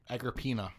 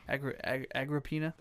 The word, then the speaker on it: Agrippina: And the target of the assassination was his mother, Agrippina the Younger. – Yes. – Okay. Did I say that correctly? – Agrippina. – Agrippina.